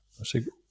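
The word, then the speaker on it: Saoutchik: Und das von dem der französische Karosserie und ich werde es falsch aussprechen, aber also man könnte es Sauschick nennen. – Ein Sauschick. – Ja, es wird Sauchick geschrieben. – Okay. – Sauschick.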